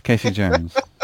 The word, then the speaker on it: Casey Jones: And as they're kind of struggling, Casey Joan appears. Casey Jones? I said it wrong. What's Casey Jones? – It's Casey Jones.